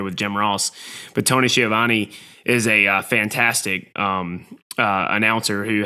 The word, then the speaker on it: Schiavone: And their play-by-play announcer, you're going to have to help me with this, make sure I say his name right. Tony Schiavone? – Tony Schiavone.